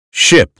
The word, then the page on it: ship: American English